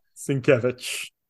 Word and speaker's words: Sienkiewicz: Sienkiewicz.